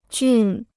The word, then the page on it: 俊: jùn